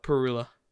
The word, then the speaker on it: Parula: I'll never I guarantee you'll never get that in my backyard, but is it pronounced Parula or Perula? – Perula.